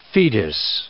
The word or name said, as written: fetus